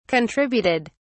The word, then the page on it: contributed: British English